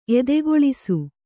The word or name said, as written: ಎದೆಗೊಳಿಸು